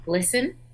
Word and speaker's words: GLSEN: So there's a great group out there called Glisten. – Uh-huh. – GLSEN, and that's how it's pronounced.